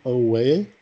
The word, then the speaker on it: Oweh: Uh, the, the, the Steelers will take Jayson Oweh.